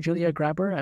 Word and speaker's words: Grabher: Um, and then probably another one I'm just looking at, um, Grabher. I think she's been Julia Grabher. I'm pretty sure that's how you say her name.